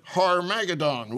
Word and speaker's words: Armageddon: Yes, Armageddon will be at our door, or as General MacArthur pronounced it, Harmageddon will be at our door.